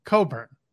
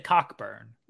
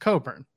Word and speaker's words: Cockburn: Coburn. – You said Cockburn. – Yeah, it's spelt Cockburn, but it's pronounced Coburn.